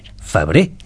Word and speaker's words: Febrer: Febrer, sense pronunciar la R final.